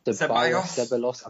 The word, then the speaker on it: Ceballos: How do you say it? Seb, Seb, Sebalos? – Ceballos. I think I think it's Ceballos.